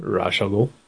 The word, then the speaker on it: Ra's al Ghul: Hey, how do you pronounce Talia al Ghul's father's name? – Ra's al Ghul. – You say Ra's al Ghul? Okay. I've always said Ra's al Ghul.